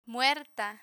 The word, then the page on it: muerta: Spanish